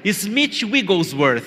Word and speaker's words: Smith Wigglesworth: Smith Wigglesworth, fala comigo, Smith Wigglesworth.